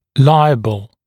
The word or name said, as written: liable